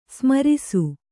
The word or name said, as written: ಸ್ಮರಿಸು